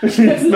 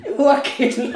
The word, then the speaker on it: Joachim: It's not Joachim Löw. It's not. Joachim. – Joachim.